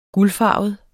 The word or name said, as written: guldfarvet